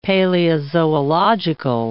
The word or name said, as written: paleozoological